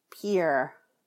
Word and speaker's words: Pierre: I found it very jarring when I moved to South Dakota to be with Fuzzy and learned the locals pronounce it Pierre.